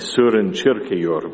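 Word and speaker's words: Søren Kierkegaard: Or I understand it should be pronounced something like Surin Chirkeyor, but I won't do that.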